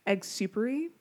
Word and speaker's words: eggsoupery: Her Instagram and her TikTok is at eggsupery, so check her out.